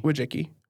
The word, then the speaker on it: Wojcicki: And, like, so the idea here is Susan Wojcicki, I think it's pronounced Wojcicki. – Wojcicki. – I could be mispronouncing it.